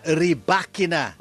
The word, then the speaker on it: Rybakina: And yes, it's pronounced Elena Rybakina.